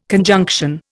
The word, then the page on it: conjunction: Indian